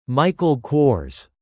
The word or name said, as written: Michael Kors